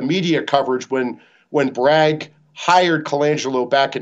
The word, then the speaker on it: Colangelo: His name is Matt Colangelo, is I think how I pronounce his last name. It's C-O-L-A-N-G E-L-O, Colangelo.